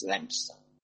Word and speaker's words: Leominster: It's spelt Leominster, but it's Leominster.